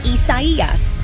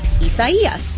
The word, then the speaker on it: Isaias: And that's Isaias. Four syllables, Isaias. Isaias. It's actually the Spanish version of the biblical name Isaiah.